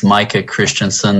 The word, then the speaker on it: Micah Christensen: Madam Chair, members of the committee, my name is Micah Christensen. That's spelled M-I-C-A-H-C-H-R-I-S-T-E-N-S-E-N.